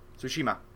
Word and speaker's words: Tsushima: Tsushima. – That's Tsushima. Easier. Tsushima. – You have to say easier. The T with the S.